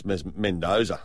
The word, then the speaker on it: Mendoza: It's Mendoza.